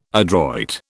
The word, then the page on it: adroit: British English